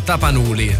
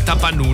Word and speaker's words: Tapanuli: Quelli degli oranghi di Tapanuli o Tapanuli. – Tapanuli, dipende come mette l'accento, però va bene uguale, ci siamo capiti.